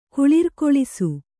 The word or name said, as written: ಕುಳಿರ್ಕೊಳಿಸು